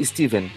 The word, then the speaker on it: Stephen: Pronuncia-se Steven. – Stephen? – É, Steven? É, o, o, o, cara que escreveu It, a coisa, por exemplo, é Stephen King.